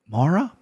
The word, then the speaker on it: Mara: Maria, is that how you say it? Mara, Mara Romero Barella, who's coming off a loss to Lauren Murphy.